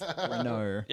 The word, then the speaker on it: Renault: So I have been corrected that the Renault 35 is not actually pronounced Renault. It's Renault.